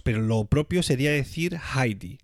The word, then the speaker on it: Heidi: En alemán, el diptongo EI se pronuncia como AI. Es decir, que si lo pronunciásemos correctamente en alemán, tendríamos que decir siempre Heidi